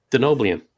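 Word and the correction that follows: Denobulan: Did I say that wrong? – Yeah, totally. – Wow. – Denobulin. – Denobulian? Whatever. Dr. Phlox.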